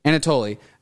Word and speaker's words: Anatoly: Anatoly,